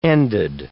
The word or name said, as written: ended